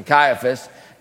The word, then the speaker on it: Caiaphas: During the high priesthood of Ananias and Caiaphas, I know how to pronounce this one, Caiaphas, the word of God came to John, the son of Zechariah, in the wilderness.